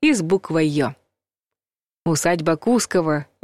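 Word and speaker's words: Кусково: А вот Кускова надо произносить с ударением на втором слоге.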